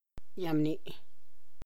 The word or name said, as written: yabni